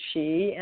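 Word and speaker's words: Sidhe: And at each stage, I think for anyone who may be familiar with the Shi, it's spelled S-I-D-D-H-E. There are several books written about communications with the Shi, and it had its roots maybe in some of the fairy and Celtic background.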